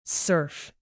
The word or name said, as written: surf